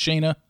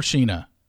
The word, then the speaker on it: Sheena: Did I call her Sheena and it's Shayna? Damn it. What is it? Is it Shayna or Sheena? It's Sheena. Okay.